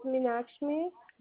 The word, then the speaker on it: Minakshmi: My first name is Meenakshmi.